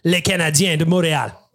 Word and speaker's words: Les Canadiens de Montréal: Tonight, the Edmonton Oilers face off against Les Canadiens de Montréal. Liam, how was the pronunciation there? – Yeah, perfect.